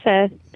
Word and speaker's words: pho: I was going to say, my favorite soup is, I believe it's a Vietnamese, I think some people think it's pronounced pho, but I asked the guy, I believe it's pronounced pho.